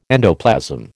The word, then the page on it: endoplasm: American English